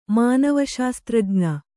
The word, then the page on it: ಮಾನವಶಾಸ್ತ್ರಜ್ಞೆ: mānava śastrajñe